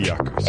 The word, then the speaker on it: Jaco: It really should just be Jaco.